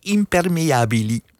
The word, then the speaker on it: impermeabili: L-J-I moet je denken. Impermeabili.